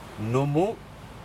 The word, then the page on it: numu: Aneityum